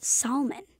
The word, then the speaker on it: salmon: S-A-L-M-O-N. – It would be pronounced salmon, but it's salmon.